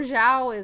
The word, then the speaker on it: Zhao: So, Chloe Zhao, or Zhao is, I think, actually how you're supposed to pronounce it.